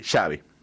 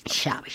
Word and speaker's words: Xavi: Sí, Xavi. – Xavi. – Xavi. Dice, como chocolate, dice.